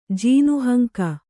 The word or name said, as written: ಜೀನಹಂಕ